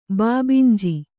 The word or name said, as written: ಬಾಬಿಂಜಿ